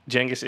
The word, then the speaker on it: Genghis: Genghis is the correct pronunciation.